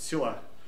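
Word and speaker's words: селах (סלה): По-еврейски это слово звучит как «селах». Ну, «хэ» в конце, «селах». «Хэ» обычно во многих языках не произносится. «А» и все. «А» делается. «А». Вот так вот. «Селах».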